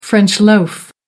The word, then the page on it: french loaf: American English